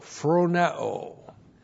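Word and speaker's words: phroneo (φρονέω): Looks like that in the Greek, that's P-H-R-O-N-E, long O, phroneo.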